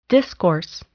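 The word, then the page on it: discourse: noun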